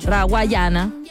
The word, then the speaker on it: Rawayana: Nosotros las tocamos mucho, así que es parte de este lineup, donde también lo comparte David Guetta, Rayawana. Yo siempre lo digo mal, Rawayana.